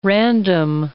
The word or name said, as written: random